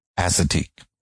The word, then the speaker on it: Assateague: Assateague.